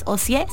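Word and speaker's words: Ocyé: mi nombre no lleva L al final. Es O si sin L, C-Y-E, acento en la E.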